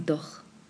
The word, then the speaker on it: doch: Fragen Sie doch. Doch. Doch. Nótese aquí la pronunciación de la CH es más atrasito en el paladar. Doch. Podríamos decir que es a la mitad del paladar. Doch. Como J. Doch.